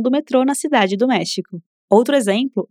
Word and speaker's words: México: Mas você conhece a palavra México, né? E provavelmente sabe que se escreve com X, mas tem o mesmo som do J do espanhol.